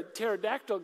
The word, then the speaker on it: pterodactyl: It's like asking the question, you know, like, why can't you hear a pterodactyl go to the bathroom? The P is silent.